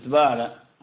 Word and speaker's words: زبالة: نعم، لكن نحن لا نفتح الزين نقول زبالة، هكذا ينطقونه، والإنسان ينادى بما سماه به أهله.